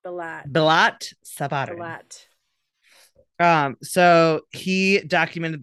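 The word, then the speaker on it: Brillat: Well, and I really want to call him Billet because that's my sister's last name. So it's really hard, but it's B-I-L-L-A-T.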